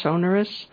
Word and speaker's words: sonorous: The cello, of course, is very deep and, how do you pronounce that word? Sonorous? – Uh-huh. – Sonorous instrument.